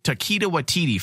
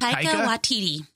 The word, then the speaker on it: Taika Waititi: The next big director for the next big feature film, I should say, is the untitled Taika Waititi film.